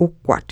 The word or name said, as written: wkwat